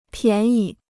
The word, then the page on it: 便宜: biàn yí